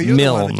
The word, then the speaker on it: Milne: I would hazard to guess that it is not pronounced Milne. I believe it's pronounced as a one-syllable word, Milne.